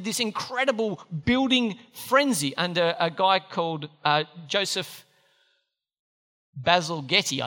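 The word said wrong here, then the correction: Bazalgette: Basil Jet.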